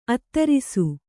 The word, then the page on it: ಅತ್ತರಿಸು: attarisu